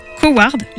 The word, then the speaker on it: Coward: le film Coward, j'espère que je le prononce bien. – Oui, c'est bien.